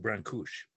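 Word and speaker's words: Brancuși: And actually, you know, he's Romanian, Brancusi, but so in Romania, his name is pronounced Brancuș.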